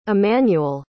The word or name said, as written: emanuel